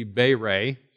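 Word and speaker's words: bere (בָּרָא): The word created in the Hebrew is bere, or bare, depending upon how you want to pronounce it. Actually, the A's are long, so it should be bere.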